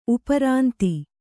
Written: ಉಪರಾಂತಿ

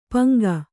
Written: ಪಿಂಗ

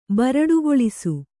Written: ಬರಡುಗೊಳಿಸು

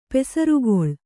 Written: ಪಸಿರ್ಗಲ್

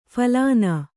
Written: ಫಲಿನಿ

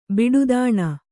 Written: ಬಿಡುದಾಣ

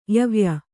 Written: ಯವ್ಯ

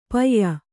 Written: ಪಾಯ

